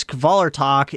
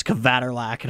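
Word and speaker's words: Kvelertak: So speaking of bands that kick ass, Kvalertak is done writing new material. And, man, I heard that band's name pronounced as Kvaterlak, and I almost lost my shit.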